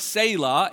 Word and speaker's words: Selah: So it's there's this word in Hebrew that's pronounced like Selah, and just in every language it's transliterated, but we don't know what it means.